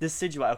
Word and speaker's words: Decidueye: How do we say this? Decidueye. Okay. Oh, you saved me. I was going to say it the complete opposite.